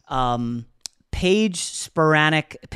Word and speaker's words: Paige Spiranac: Paige Spiranac.